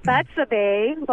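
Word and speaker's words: Bethshabe: Well, my name is Betsa Bay, but they call me Betsa.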